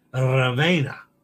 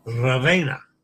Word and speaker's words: Ravenna: Roll the R. Ravenna. Ravenna. Mind your E in Italian. Yeah. It's pronounced A, like in day.